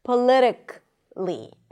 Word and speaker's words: politically: It ends with a k sound and then li. Practically. Practically, even though you still write the A, but it's not practically. Politically, not politically. It's probably not a mistake to pronounce the schwa, but usually people don't pronounce it. They just connect the k and the L.